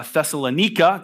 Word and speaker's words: Thessalonica: Paul and Silas are in uh, Thessalonica. It's actually that's how it's pronounced.